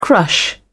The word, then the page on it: crush: British English